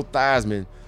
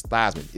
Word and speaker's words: Theismann: His last name is Theismann. It's actually Theismann, but they changed it to rhyme with Heisman.